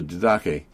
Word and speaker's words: Didache: And they even the apostles, I'm pretty sure it was the apostles, had put together a little book called the Didache. D-I-D-A-C-H-E is kind of how you would pronounce it.